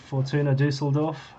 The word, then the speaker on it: Fortuna Düsseldorf: Fortuna Düsseldorf, is that correct? – Fortuna Düsseldorf.